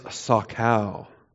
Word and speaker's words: sakal: The verb is sakal. S-A-K-A-L. And it's the soft S. It's not the sh. It's the s. In other words, it's seen, not sheen.